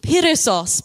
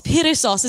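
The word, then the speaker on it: perissos (περισσός): But I think, based on my research, this word is pronounced perissos, perissos.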